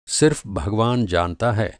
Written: सिर्फ़

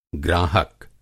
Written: ग्राहक

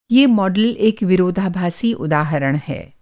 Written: विरोधाभासी

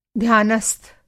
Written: ध्यानस्थ

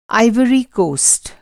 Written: आइवरी कोस्ट

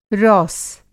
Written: रौस

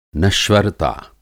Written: नश्वरता

